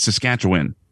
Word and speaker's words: Saskatchewan: It's Saskatchewan.